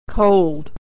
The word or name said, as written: cold